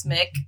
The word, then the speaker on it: McK: It's Mick, not Mac.